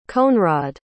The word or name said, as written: koenraad